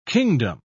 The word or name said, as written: kingdom